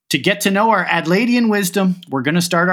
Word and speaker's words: Adelaidean: Fiona, Adelaidean wisdom? Does that sound right? – Almost right. We tend to slur all our syllables together, so it's an Adelaide wisdom, Adelaidean wisdom. Yep.